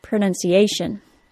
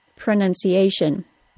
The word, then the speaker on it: pronunciation: The word pronunciation is rather long. It has five syllables. Pronunciation. Luckily, the T-I-O-N suffix tells us which syllable receives the stress. Words that include T-I-O-N, as well as S-I-O-N, are stressed on the syllable before the T-I-O-N.